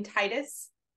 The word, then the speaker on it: Titus: Do you say your last name Titus? – I do.